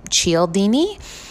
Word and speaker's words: Cialdini: So social proof is actually a term that was coined by an author named Robert, I believe you pronounce his last name, Cialdini.